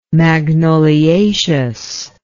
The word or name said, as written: magnoliaceous